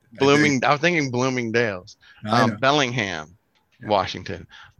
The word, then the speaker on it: Bellingham: from, is it Bloomingham? Bloomingham, Washington, am I saying that right? I'm way off, aren't I? – Bellingham.